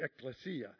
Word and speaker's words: ecclesia: When he said, I will build my church, the word is, and I had a Greek brother correct me this morning because I called it the ecclesia, and he says it's the ecclesia, and I said, oh, that's good, and right, you just got to get the emphasis on the right syllable, obviously.